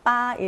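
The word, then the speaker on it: 빠 (八): 빠 일성인데요. 이 돈을 벌다의 파차이, 파차이 할때이 파와 발음이 빠, 파 발음이 비슷하잖아요. 예, B랑 F만 다르고 성조도 똑같습니다.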